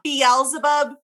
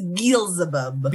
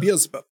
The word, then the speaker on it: Beelzebub: It's not Beelzebub. That's how Freddie Mercury says it, which is way more fun. Like, that extra syllable just adds to it. So. – You know, like, that's a way more fun to say, but it is Beelzebub.